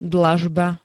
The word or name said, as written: dlažba